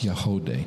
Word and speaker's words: Yagody: Y-A-G-O-D-Y, but it's pronounced Yahodeh.